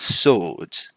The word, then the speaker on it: sword: Double-edged sword. So, mind the pronunciation here. It's not sword. Here, that word. It's not sword. That one. Blimey. That word. It's not sword. It's sword. Sword. Double-edged sword.